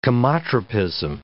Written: chemotropism

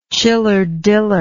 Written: chiller diller

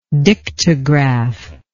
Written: dictograph

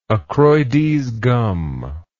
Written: accroides gum

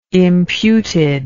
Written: imputed

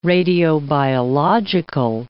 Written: radiobiological

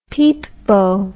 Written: peepbo

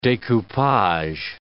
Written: decoupage

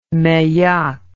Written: meilhac

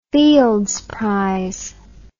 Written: Fields Prize

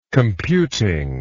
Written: computing

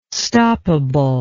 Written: stoppable

